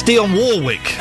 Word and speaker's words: Dionne Warwick: It's Dionne Warwick. You pronounce the second R. It's not an invisible R.